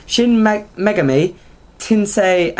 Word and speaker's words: Shin Megami Tensei: Shin Megami, Tensei, and Persona. – Where were you looking? – Shin. – Alright, Shin Megami Tensei and Persona.